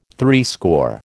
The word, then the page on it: threescore: American English